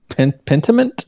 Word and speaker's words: Pentiment: I think it's called Pentiment? – Yep, that's right. – I believe that's what it's called, yeah. – I always want to put an R in it, but I don't think there's an R in it.